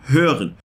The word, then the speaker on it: hören: Então repete comigo: hören hören.